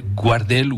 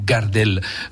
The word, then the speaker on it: Gardel: Guardel ou Gardel, selon comment on le prononce, Carlos Gardel que connaissent sûrement les anciens, qui était un Toulousain et qui chantait du tango.